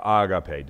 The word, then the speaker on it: agape: And that word is agape, or agape, depending on how you want to pronounce it.